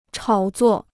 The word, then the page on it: 炒作: chǎo zuò